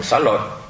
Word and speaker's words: Xá Lợi: Xá Lị nhiều khi cái âm chữ Lợi, chữ Lị nó viết giống nhau, thì mình đọc là Xá Lợi, con bà Xá Lợi chẳng hạn.